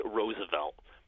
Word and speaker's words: Roosevelt: And that was the common pronunciation for a lot of people, but Teddy Roosevelt himself insisted, no, it is pronounced Roosevelt. So a little bit of a controversy, but that's how Teddy Roosevelt said his name. He said, like the flower, Roosevelt.